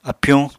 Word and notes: apyuñ: Apatani

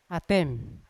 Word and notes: atem: Yawa

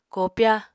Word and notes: koopya: Apatani

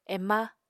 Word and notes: ema: Apatani

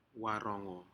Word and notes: warogo: Aneityum

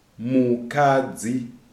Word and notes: Mukadzi: Shona

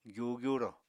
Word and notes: gyu-gyuro: apatani

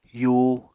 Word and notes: yuu: apatani

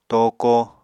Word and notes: tooko: Apatani

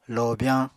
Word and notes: lobyañ: Apatani

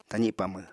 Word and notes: tazi pamw: apatani

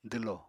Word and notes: dwlo: apatani

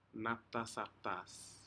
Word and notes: naptasaptas: Aneityum

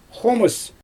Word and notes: xomɯs: Xyzyl

